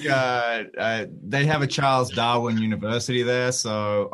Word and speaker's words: Darwin: Hey, yeah, it's pronounced Darwin with like three A's.